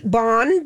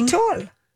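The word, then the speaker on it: Bonne Étoile: Bon, B-O-N-N-E. – Okay, Bon. – Atoll. E-T-O-I-L-E.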